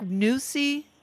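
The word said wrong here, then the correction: Neuse: Is that how it's? – Noose River. – Noose River, and one of the first riverkeepers in the Southeast.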